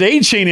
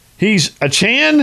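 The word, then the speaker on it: Achane: He doesn't pronounce it A-Chain anymore like he did at Texas A&M. I'm listening to that and watching the game, and I'm going, hold on a minute. Is he no longer A-Chain? He's A-Chan? I mean, I had no idea he changed the pronunciation.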